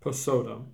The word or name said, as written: Pesotum